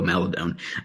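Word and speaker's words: Maledon: Is it Mount? Is it Maldon? I don't know how to say his last name. – Maladon. So we always said Maladon also, but we found out it is Teo Maladon.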